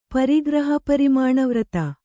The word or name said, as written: ಪರಿಗ್ರಹಪರಿಮಾಣವ್ರತ